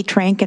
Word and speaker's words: Trenk: Mr. Chair, members of the committee, for the record, my name is, Peggy Trank, and I'm here on behalf of the Treasure State Resources Association. That's spelled T-R-E-N-K.